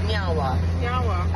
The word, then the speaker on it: nyawa: So in our language, we say nyawa, which means thank you. – Nyawa. – I said it right? – Yeah, you did.